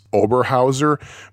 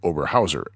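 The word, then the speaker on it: Oberhäuser: But yeah, at first glance, it looks like it's Oberhauser, but the A has an umlaut over it. And A with an umlaut followed by a U, according to this book, if I remember correctly, is actually pronounced Oi.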